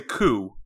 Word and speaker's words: coup: So when the government does a coup, you don't pronounce the P.